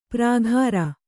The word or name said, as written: ಪ್ರಾಘಾರ